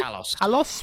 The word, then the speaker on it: Talos: The Talion's Principle 2, if I said that correctly? – Talos, thank you.